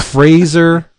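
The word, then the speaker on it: Frazer: So I never bought Xombi with an X, X-O-M-B-I, Xombi, by John Rozum. This new series is written by Rozum with art by the unbelievable Fraser... rhymes with laser, Irving.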